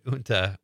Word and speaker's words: junta: You use the word junta, which is spelled J-U-N-T-A. And I remember a classmate of mine in high school was reading and he said junta. Because he missed the lesson the day before where the rest of us learned how to pronounce it.